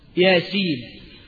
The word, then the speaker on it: يس: ولا تنطق هكذا بل تنطق باسم الحرف ياسين، مش كده ولا ايه؟